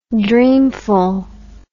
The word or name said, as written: dreamfulness